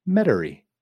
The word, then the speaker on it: Metairie: You know, just, and then I think after that, I'm going to Metairie.